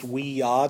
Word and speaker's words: Ouija: It's technically supposed to be pronounced "wee-ya." Do you know that? It's yes, yes in French and German.